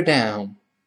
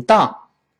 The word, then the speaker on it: down: 放在一起，我们跟老师读一遍：Is two blocks further down？顺便老师再说一句，就是这个 down。好多咱们中国人容易把它读成当，当，注意不是当，中间不是 on，而是发双元于 l。